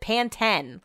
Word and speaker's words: Pantene: Not the slogan there, also not how you pronounce it there. They pronounce it Pantene. Like, I'm not just talking about that's how people say it and they're saying it incorrectly. That's how it's said in the commercials, which really got me thinking.